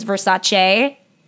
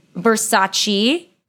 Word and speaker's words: Versace: Like, basically, she got her makeup done at the Versace show and made a video about it for the Versace account, and I'm pronouncing it the way that it's supposed to be pronounced, apparently, which is Versace, but she pronounced it Versace.